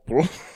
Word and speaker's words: Paul: That's how the French say it. – Right. That's French pronunciation. Read a book. – Yeah, you just say it. Boom. It's almost Paul. I mean, it's almost Paul, but it's also almost pool. It's definitely not Paul.